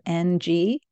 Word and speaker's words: Ng: Her last name is spelled N-G, but it's pronounced Ng, like I-N-G.